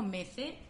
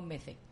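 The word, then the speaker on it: Mezze: Bueno, nosotras le decimos Metze porque lo leemos en el idioma que hablamos, entonces lo pronunciamos Metze. – Metze. – Es una palabra con mucho... con muchos orígenes, entonces entiendo que en cada país lo pronunciarán de una forma. Nosotras aquí en Granada lo pronunciamos Mece.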